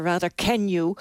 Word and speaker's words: Raducanu: De 19-jarige Leila Fernandez en de 18-jarige Emma Raducanu. Zeg ik het goed, Marcelle Mesker? Goedenavond. – Goedenavond. Ja, in de Groot-Brittannië zeggen ze rather can you.